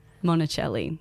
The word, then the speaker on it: Monacelli: My name is Maddie, M-A-D-D-Y, Monacelli, which is spelled M-O-N-A-C-E-L-L-I.